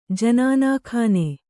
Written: ಜನಾನಖಾನೆ

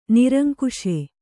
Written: ನಿರಂಕುಶೆ

